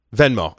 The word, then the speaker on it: Venmo: Is it Venmo? Did I say that right? Venmo? – Vimo? Venmo? – I don't remember. Yeah. Venmo. I did say it right.